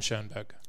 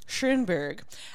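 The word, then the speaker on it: Schönberg: How do you pronounce it? – Bubel and Schoenberg. – Schoenberg. And it has the umlaut.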